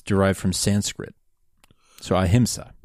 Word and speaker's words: ahimsa: And then episode four is Amisa? I'm not sure how to pronounce that. A-H-M-I-S-A. – Ahimsa. – Ahimsa, you're right. I misread that.